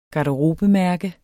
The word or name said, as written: garderobemærke